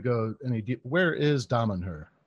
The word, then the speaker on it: Damanhur: Damanhur.